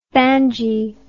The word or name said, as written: banji